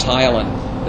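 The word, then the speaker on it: ptyalin: For example, amylase used to be called Tylan. That's, so you may run across that Tylan. It starts with a P, it's a silent P.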